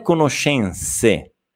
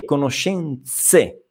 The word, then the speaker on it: conoscenze: Attenzione a questa Z. Non è conoscenze, è conoscenze.